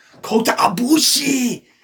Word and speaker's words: Kota Ibushi: No, you say it like you're in a bath and it's hot. Kota Ibushi.